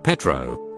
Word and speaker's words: Petro: You could also hear chanting from multiple people repeating one word, "Petro," pronounced like Pedro.